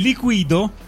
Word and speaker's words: Liquido: Il primo pezzone erano i Liquido con l'accento sulla I con Narcotic.